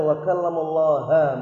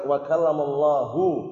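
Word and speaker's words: wa kallamallahu (وَكَلَّمَ اللَّهُ): Ayat itu bacanya wa kallamallaha Musa taklima. Artinya Nabi Musa mengajak bicara Allah Subhanahu wa taala dengan sebenar-benar pembicaraan. Keliru enggak ini? Keliru. Yang tepat wa kallamallahu sebagaimana yang sering kita jumpai dan itulah lafaz yang benar dan harokat yang benar.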